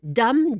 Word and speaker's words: dam: Gardons-nous d'écrire le mot avec un E final, ce dame, D-A-M, que les puristes font rimer avec dans, D-A-N-S et dans, D-E-N-T, alors que les autres le prononcent comme dame.